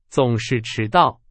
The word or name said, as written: 总是